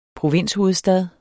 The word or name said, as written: provinshovedstad